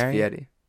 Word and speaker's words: Fieri: It's Fieri.